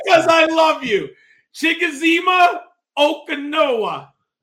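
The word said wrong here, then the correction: Chigoziem Okonkwo: That's not bad. I think it's Chigazim Okonkwo. I believe is how you say it.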